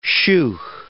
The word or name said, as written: sheugh